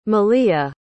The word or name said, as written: maleah